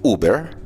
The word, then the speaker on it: Uber: La cosa se puso buena cuando entendimos que se pronuncia Uber